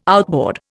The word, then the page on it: outboard: Indian